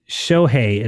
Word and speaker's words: Shohei: Shohei is how it's pronounced.